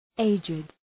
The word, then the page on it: aged: ‘eıdʒıd